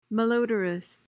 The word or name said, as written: malodorous